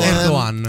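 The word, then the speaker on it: Erdoğan: potuto fare perché da persona insopportabile, insolente e presuntuoso, Erdogan so che non si pronuncia. – Ah, pensavo Erdogan. parlassi di te. Si pronuncia ehm, Erdogan.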